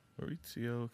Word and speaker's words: Maurizio: It may be a Maurizio. – Maurizio. – There's no T, but it might be pronounced like that. Which I like that. – Is there a Z? – Good content. No, it's a Z. – That's Maurizio. What I said.